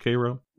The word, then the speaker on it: Cairo: In deep southern Illinois, are we talking Cairo, spelled Cairo, but pronounced Cairo? – We're talking about an hour north.